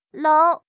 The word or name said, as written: lou2